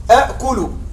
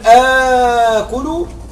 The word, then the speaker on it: آكل: هذا هو الأصل نعم لكن لا ننطقه. ماذا ترى؟ همزتان الثانية ساكنة تقلب حرف مد من جنس الفتحة، خلاص نقلبها ألف، فأقول آكل وآخذ تسهيل.